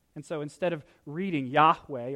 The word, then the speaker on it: Yahweh: God identifies himself as YHWH, and we think that it's pronounced something like Yahweh, but nobody knows for certain because the original Hebrew lacks the vowel pointers.